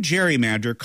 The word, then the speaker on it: gerrymander: But when the term gerrymander caught on, it wasn't with the hard G sound of Gary's surname.